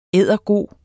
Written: eddergod

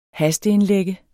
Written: hasteindlægge

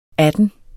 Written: atten

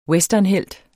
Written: westernhelt

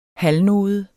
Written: halvnode

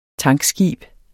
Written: tankskib

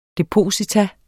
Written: depositum

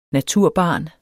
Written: naturbarn

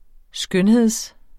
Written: skønhed

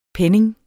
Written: penning

